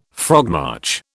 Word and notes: frogmarch: British English

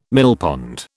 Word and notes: millpond: British English

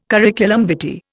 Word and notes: curriculum vitae: Indian

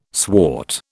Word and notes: swart: British English

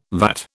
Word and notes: VAT: British English

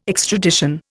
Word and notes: extradition: Indian

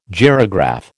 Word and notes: gyrograph: American English

